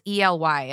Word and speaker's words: Ely: Is it the Ely Cathedral? The Ely Cathedral? It's ELY. It's in England. – It's Ely. – Ely.